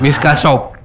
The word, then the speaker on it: Miss K Shop: Ada yang nanya tuh dari Miss K apa Ki ini dibacanya? – Miss K Shop. – Miss K berarti shop. Inggris K-nya.